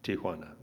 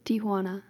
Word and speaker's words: Tijuana: Can you say it right for us? – Tijuana? – Tijuana. Very good. You got... – Tijuana. – Very good. Good job, guys.